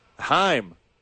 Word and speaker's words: Heim: It's not Johan Hamm. – No. Heim.